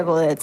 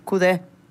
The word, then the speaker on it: Coudet: Si uno lo fuera a decir de manera correcta, no se pronuncia Coudet. Sería tipo Coudet.